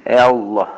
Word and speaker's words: Allah: Düz, onu yaxşı olardı ki, sen bizim bu hafiz kardeşlerden soruşasan, ben onu bilirim ki, bəli, orada düzgün telaffuz olması Allah'tır. Allah, yani dalınca A.